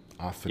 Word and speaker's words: Ophanim: Ophanim. – Yeah. The Ophanim. That is always a long O sound in Hebrew.